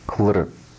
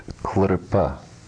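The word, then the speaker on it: kḷp: Klrp, klrp. Notice the diagonal stroke immediately following the letter pa. This indicates there's no vowel sound. If there were no diagonal stroke there, it would be pronounced klrpa.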